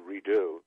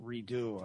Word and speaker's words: Redux: By the way, the title is a mispronunciation. It's Redo. – Oh, I'm sorry. Okay. My error, R-E-D-U-X, pronounced Redo.